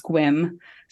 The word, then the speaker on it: Sequim: And then there's a town in Washington that's spelled S-E-Q-U-I-M, which you would say that's Sequim, right? It's pronounced Squim.